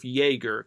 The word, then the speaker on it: Jaeger: I think it's the same thing with Jaeger, because the J is pronounced like a Y, because J is pronounced as a Y in German. – Right. – I don't know how Although John, that his name is spelled J-E-A-N, but instead of being pronounced Gene, it's John.